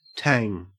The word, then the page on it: tang: Australian English